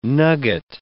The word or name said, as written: nugget